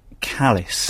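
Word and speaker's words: Callies: I believe it's pronounced Callis.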